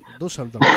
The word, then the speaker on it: Düsseldorf: Es de, no sé cómo se pronuncia, supongo que es Düsseldorf o algo así. – Düsseldorf.